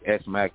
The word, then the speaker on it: S-Mac: spelled E-S-M-A-C, but I'm from the East Side, so I spelled the E-S, you know what I mean? But it's pronounced S-Mac, which was short for Snoop Mac, you feel me? – Mm-hmm.